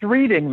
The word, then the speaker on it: Reading: It's pronounced Reading though.